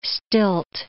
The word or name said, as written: stilt